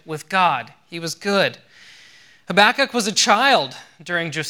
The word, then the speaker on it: Habakkuk: Habakkuk, that's how you say it. Sarah did a great job. I Googled it also myself to find out. It's not readily obvious, I assure you. Habakkuk, he was a prophet.